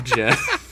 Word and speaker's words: Geoff: G-E-O-F-F is like the evil mirror universe version of Jeff.